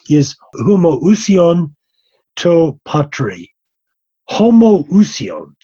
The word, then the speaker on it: homoousion: And in Greek, there are two words that are very closely pronounced. One is homoousion, and the other is homoiousion. Homoousion means the same being the homoiousion means a like being.